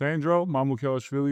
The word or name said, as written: Sandro Mamukelashvili